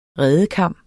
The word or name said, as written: redekam